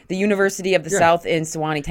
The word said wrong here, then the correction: Sewanee: Tennessee. – Sewanee. – There we go. I knew I was saying it wrong.